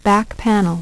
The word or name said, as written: back panel